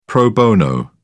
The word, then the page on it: pro bono: British English